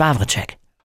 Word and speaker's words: Wawrczeck: Und ich bin Jens Wawritschek.